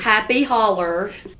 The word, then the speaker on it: Holler: And Happy Holler, H-O-L-L-E-R, is the way that's spelled. But it's not just our accent. – Yeah. – It is Happy Holler.